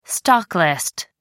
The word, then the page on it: stocklist: American English